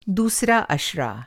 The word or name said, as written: अशरा